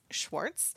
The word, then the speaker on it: Schwarz: I am excited to introduce you to Ace Schwarz from Teaching Outside the Binary.